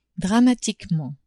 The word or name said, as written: dramatiquement